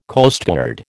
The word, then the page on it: Coastguard: American English